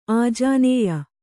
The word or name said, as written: ಆಜಾನೇಯ